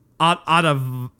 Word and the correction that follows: Ottaviani: Viana, The, uh, Ottaviani? Uh, Ottaviani? I don't know. – Guido Ottaviani. I'm so sorry that I butchered that so bad.